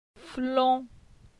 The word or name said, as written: Flon